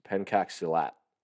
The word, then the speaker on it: Pencak Silat: Now, I will pronounce this correctly. It's Pencak Silat.